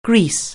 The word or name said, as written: grease